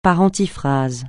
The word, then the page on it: par antiphrase: francais